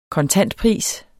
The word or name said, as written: kontantpris